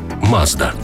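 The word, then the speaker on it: Мазда (松田): Назва також походить від імені засновника компанії Мацуда, яке японською вимовляється як Мазда.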